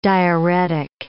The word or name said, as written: diarrhetic